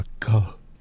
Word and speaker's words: Lacan: Lacan, for instance. – All right. – I can't say his name correctly. L-A-C-A-N. – Mm-hmm. – Jacques Lacan. It's probably Lacan. You know, you swallow the N.